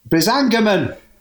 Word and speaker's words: Brisingamen: By the way, Steve, I'll let you off because I said it completely wrong until I met Alan Garner. – Did you say it wrong to him? – Yeah, I always called it the weird stone of Brisingamen. – Right. – And he said, "What, you mean the weird stone of Brisingamen?" Like, yeah, of course, that's exactly what I meant.